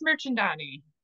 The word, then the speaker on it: Mirchandani: Sure, my name is Grace Mirchandani.